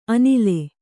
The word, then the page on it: ಅಣಲ್: aṇal